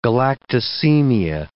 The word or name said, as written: galactosemia